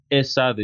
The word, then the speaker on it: ESADE: I think you did, Escade, am I pronouncing it correctly, in Barcelona? – ESADE, E-S-A-D-E.